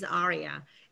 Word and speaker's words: AREAA: Well, ARIA, thank you so much, everybody, to pronounce this A-R-E-A-A. In the correct way. A lot of people, you know, pronounce it wrong. Yes, it's ARIA.